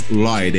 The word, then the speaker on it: Lloyd: Es, la pronunciación correcta es Lloyd, ¿eh? Lloyd.